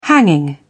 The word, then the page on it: hanging: British English